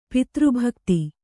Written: ಪಿತೃಭಕ್ತಿ

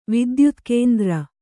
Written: ವಿದ್ಯುತ್ಕೇಂದ್ರ

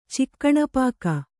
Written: ಚಿಕ್ಕಣಪಾಕ

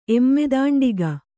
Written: ಎಮ್ಮೆದಾಂಡಿಗ

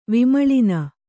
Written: ವಿಮಳಿನ

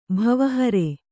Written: ಭವಹರೆ

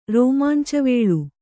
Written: ರೋಮಾಂಚವೇಳು

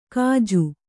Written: ಕಜ್ಜು

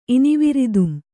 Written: ಇನಿವಿರಿದುಂ

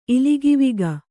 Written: ಇಲಿಗಿವಿಗ

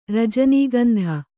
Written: ರಜನೀಗಂಧ

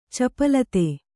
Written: ಚಾಪಲತೆ

